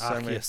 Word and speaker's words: Arceus: It's spelled A-R-C-E-U-S. – Arceus, so I believe. – Arceus, because hopefully I won't be wrong.